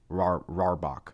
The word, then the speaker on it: Rohrbach: R-O-H-R-B-A-C-H. Rohrbach.